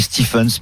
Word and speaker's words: Stephens: Alors ça s'écrit Stephens, mais ça se dit Stephens.